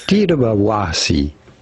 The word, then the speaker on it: Tittabawassee: Tittabawassee. Tittabawassee. Tittabawassee. Tittabawassee. Tittabawassee. Tittabawassee. Tittabawassee. – What's weird is that, like, you just have to say Tittabawassee. Tittabawassee. It's on the wa. That's the emphasis.